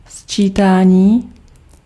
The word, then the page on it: optellen: /op.tɛ.lǝⁿ/